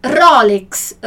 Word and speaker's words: Rolex: Allora, Rolex, tá vendo aqui? Rolex. A gente pronuncia de maneira completamente diferente, né? A tônica aqui, né? A sílaba forte no italiano é no ro, né?